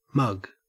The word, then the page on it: mug: Australian English